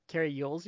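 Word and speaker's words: Cary Elwes: This came out. Carrie Elwes. – Carrie Yules, you mean? – Yes, I'm sorry.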